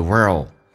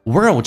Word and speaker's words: world: Precious thing in the world. World 这个单词非常难发，在美式发音需要重度卷舌，整个舌身往回卷 world,